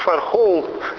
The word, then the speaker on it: Firehole: Вот, читается как Firehole, то есть дырка в фаерволе или что-то в этом духе.